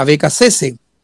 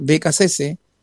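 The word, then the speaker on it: Beccacece: Ahora, yo creo que a Becasese, como realmente se le tiene que pronunciar, Becasese.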